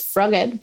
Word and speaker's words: Froged: Yeah, so the story about Froggit, in fact, we pronounce Froggit because it's Ed the Frog.